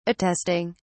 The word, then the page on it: attesting: British English